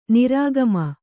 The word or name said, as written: ನಿರ್ಗಮ